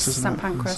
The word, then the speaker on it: St Pancras: I said it wrong. I said pancreas, and then it's not St Pancras. St Pancras.